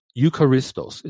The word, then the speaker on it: eucharistos: So the word thankful in Colossians 3:15 is the Greek adjective eucharistos. Eucharistos is spelled E-U-C-H-A-R-I-S-T-O-S, eucharistos, which translated means grateful or thankful, okay?